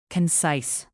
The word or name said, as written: concise